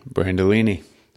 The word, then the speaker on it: Brandolini: Brandolini.